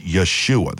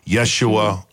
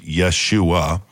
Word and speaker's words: Yeshua: In Hebrew, it's Yeshua. This is how you pronounce it. Yeshua. I've heard both ways. Yeshua, which is a derivative of Yehoshua, where we get the word what? – Joshua.